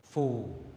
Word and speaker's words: phù: Chữ phu mà viết đầu câu thì đọc là phù.